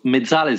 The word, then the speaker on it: mezzala: Well, it depends how you play, because mezzala is very used in Italy, and you pronounce it perfectly, by the way.